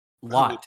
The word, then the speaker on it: Lath: Lot. You say his name Lot. L-A-T-H. Lot. Lot. Yeah, okay. It's wild. – Well, he takes a whole lot of space. – L-A-T-H, yes.